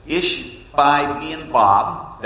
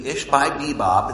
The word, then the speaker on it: Ishbi-benob: Now, you have Lamai, you have Ishbi-benob, that's actually the name, Ishbi-benob, it's how it's pronounced.